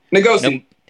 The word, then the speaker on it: Ngomsi: And you can pronounce her name. I'll spell it V-I-N-C-I-A-N-E. Vinciane Ngomosi? – Ngomosi. – Ngomosi, mm-hmm. N-G-O-M-S-I.